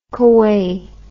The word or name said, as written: kueh